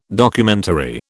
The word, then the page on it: Documentary: British English